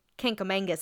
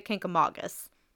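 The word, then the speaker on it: Kancamagus: which eventually turns into the Kinkamangus Highway. Now, I know it's not pronounced that way, but that's how we always pronounce it, but it's really the Kinkamagus.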